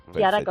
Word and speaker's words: Kiara: He dicho bien tu nombre, ¿no? Porque creo que se escribe con CH. – Sí, sí, pero me lo pusieron con K a mí, para que no hubiera problemas. Kiara con K.